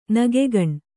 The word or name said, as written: ನಗೆಗಣ್ಣು